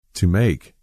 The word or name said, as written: To make